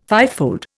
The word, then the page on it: fivefold: Indian